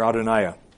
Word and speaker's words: Adonijah: He omitted how Solomon's place on the throne was nearly lost to his scheming half-brother Adonijah. Or Adoniah. That's actually how you pronounce it.